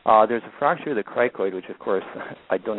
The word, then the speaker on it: cricoid: The fracture of the cricoid, I hope I'm pronouncing that correctly.